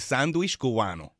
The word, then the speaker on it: Sandwich Cubano: It's the Cuban grandmother pronunciation, by the way. Sandwich, El Sandwich Cubano.